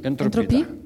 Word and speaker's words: Entropie: Entropie? – Да, да, так и говорит.